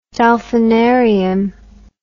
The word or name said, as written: dolphinarium